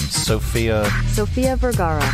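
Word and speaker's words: Sofía Vergara: Sophia Vergara.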